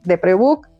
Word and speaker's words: Deprebook: Oye, también, también en el comercial, este, a quienes escuchen esto y nos escriban así de, las escuché en en el de Prebook, ¿Sí lo dije bien? – Sí lo dijiste bien.